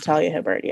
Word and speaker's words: Talia Hibbert: But I think like for Talia Hibbert, am I saying that right? Talia? – I believe it's Talia Hibbert. Yeah.